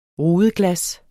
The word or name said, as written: rudeglas